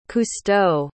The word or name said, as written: cousteau